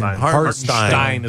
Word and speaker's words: Hartenstein: Hartenstein is the way they pronounce it. Stein.